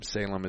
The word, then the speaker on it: Salem: Salem is spelled like the cigarette.